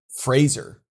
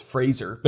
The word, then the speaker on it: Fraser: It is definitively Fraser. Brendan Fraser. That's how it's pronounced. According to him. I didn't know that. – No. I've always screwed that up. I've always pronounced it like Joe Fraser, which had a Z, and it's not. It's Fraser.